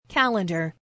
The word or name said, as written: calendar